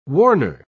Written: warner

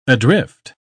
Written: adrift

